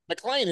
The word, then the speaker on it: McLean: So, first of all, you grew up in McLean, Virginia. – McLean. – It's spelled McLean.